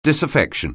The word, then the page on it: disaffection: anglais